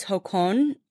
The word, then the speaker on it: jocón: It's jocón, which is J-O-C-O-N. And there's an accent on that second O.